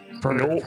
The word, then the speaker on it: Firbolg: I, like I said, I don't have too much experience with the Firbolgs. Firbolg. Yeah. I'm not saying it right at all, am I? Firbolg? – Firbolg. – Bolg. Oh, Firbolg. – There you go.